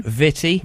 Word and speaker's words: Vitty: Vitti. – Vitti. – Vitti. – Titty. – Vitti. – Witty. – Vitti with a V. – Vitti. How do you spell that? – V-I-T-T-Y.